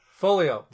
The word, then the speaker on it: Foglio: Foglio.